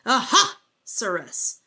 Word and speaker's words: Ahasuerus: And I remember um, whenever I was really young, my mother would read the Bible to us and she would go, This is how you pronounce it Ahasuerus.